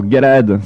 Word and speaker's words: Galaad: Voilà, alors Galade, ça s'écrit G-A-L-A-A-D.